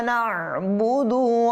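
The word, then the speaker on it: না'বুদু (نعبد): অনেকে এটাকে বলে না'বুদু, না'বুদু, না'বুদু বললে আইনটা পড়ে গিয়েছে, সেহেতু আমাদেরকে এখানে আইনটাকে ভালোভাবে উচ্চারণ করতে হবে, না'বুদু, না'বুদু। হ্যাঁ, যদি না'বুদু বলে আইনটা চলে যাচ্ছে, তাহলে হবে না।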